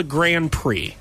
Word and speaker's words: Grand Prix: And I'm like, first of all, it's called a Grand Prix. – Oh. – Even though it's spelled P-R-I-X, it's a Grand Prix.